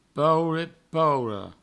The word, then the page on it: paure, paura: paure, pauro; provencal